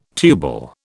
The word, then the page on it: tubal: British English